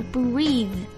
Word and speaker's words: breathe: 这个动词是 breathe， breathe。大家要注意这两个单词的发音的区别， breathe， breath。